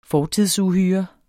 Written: fortidsuhyre

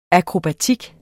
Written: akrobatik

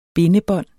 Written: bindebånd

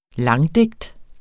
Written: langdigt

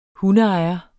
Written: hundeejer

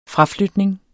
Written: fraflytning

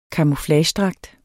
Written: CAMOUFLAGEDRAGT

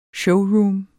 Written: showroom

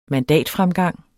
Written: mandatfremgang

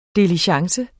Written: DILIGENCE

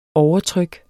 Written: overtryk

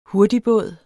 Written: hurtigbåd

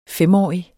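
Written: FEMÅRIG

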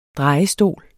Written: DREJESTOL

